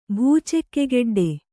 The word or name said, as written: ಭೂಚೆಕ್ಕೆಗೆಡ್ಡೆ